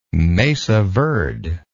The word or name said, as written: mesa verde